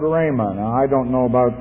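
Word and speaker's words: rhema (ῥῆμα): and that's the word chrema or rhema, which is an easier English pronunciation.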